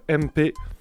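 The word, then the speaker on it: EMPE: Alors, euh, pour l'instru de la semaine, on va écouter un beatmaker qu'on n'a jamais écouté, euh, MP. Alors, ça s'écrit pas M et P, s'écrit E-M-P-E. E.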